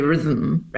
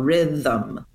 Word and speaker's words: rhythm: But yeah, that T-H-M, it's a two-syllable word. There's no doubt about it. We don't say rhythm, right? Like there is a second vowel, rhythm, 1, 2. And the thum syllable has a schwa vowel.